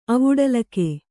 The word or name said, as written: ಅವುಡಲಿಕೆ